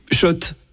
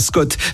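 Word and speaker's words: Schott: Oui, Shot. Et pas Scott, ça fait des années qu'on prononce mal la marque.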